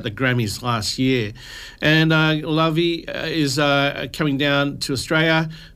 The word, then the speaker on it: Laufey: So this song that I'm going to play for you now comes from Lavey, spelled Laufey, L-A-U-F-E-Y.